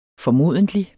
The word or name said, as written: formodentlig